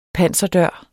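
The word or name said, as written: PANSERDØRE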